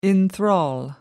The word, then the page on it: enthrall: British English